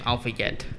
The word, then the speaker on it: Al-Fayed: And I believe that's how you pronounce his last name, Al-Fayed.